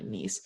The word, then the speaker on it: Nice: I always forget that it's pronounced actually Nice, because I try to read it in English.